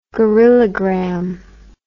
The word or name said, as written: gorillagram